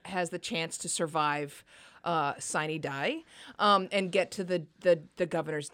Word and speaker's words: sine die: Now, sine die is how people in Georgia pronounce it. It comes from Latin, and if you were pronounce it according to Latin guidance, it would be sine die.